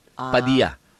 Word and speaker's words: Padilla: Ay, mga Amerikano, hindi nila pinapronounce yung double L. – Hindi yun. Sa mga Espanyol at mga Amerikano, yeah yun. Padilla. Parang Poyo Loco. Double L.